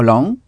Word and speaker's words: long: But sans prononcer le G. How long? – How long?